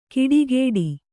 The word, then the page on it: ಕಿಡಿಗೇಡಿ: kiḍigēḍi